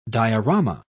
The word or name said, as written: diorama